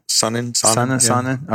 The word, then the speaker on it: Sonnen: Sonnen, is that how you say his name? – Sonnen.